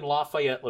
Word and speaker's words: Lafayette: There's one in Lafayette, Louisiana. – Okay, that's over an hour away. – Lake Charles. – 2 hours away. – So all I did was. – By the way, good pronunciation of Lafayette.